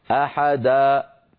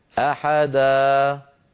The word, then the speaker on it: أحدا: أريد أن أنبه على شيء، يعني هذه لما نقف على مد العوض علينا أن ننتبه ألا نختم صوتنا بقفل الوترين الصوتيين، يعني اسمعوني سأنطق الخطأ، وكان الله عليما حكيما، اسمعوا، حكيما، أحدا، أحدا، أنا الآن تعمدت أن أقفل الوترين الصوتيين. لما تعمدت أفعل ذلك ماذا سمعتم في آخر نطقي؟ سمعتم همزة، هذه الهمزة سببها قفل الوترين الصوتيين. فعلينا ألا نقفلهما ونترك بينهما مجالا لمرور الصوت والهواء. هكذا: أحدا عليما حكيما، ما أحدا.